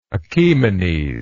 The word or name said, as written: achaemenes